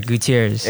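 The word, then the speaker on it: Gutiérrez: His name is Dominic Gutierrez, right? Did I say that right? – Yeah, Gutierrez.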